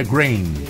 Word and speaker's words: Grain: é como se fosse grão em inglês. – É grain.